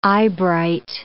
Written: eyebright

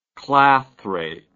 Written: clathration

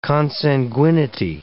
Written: consanguinity

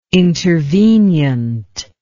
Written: intervenient

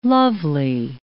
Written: lovely

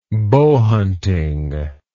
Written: bowhunter

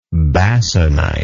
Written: basanite

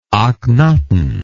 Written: akhnaton

